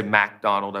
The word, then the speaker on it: MacDonald: I should say MacDonald, I believe is how it's pronounced.